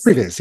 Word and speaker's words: previs: Did you guys see the previs from, did I say that right? Previs? – Previs, yep. Previs.